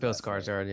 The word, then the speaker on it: Skarsgård: Bill Skarsgård, yeah.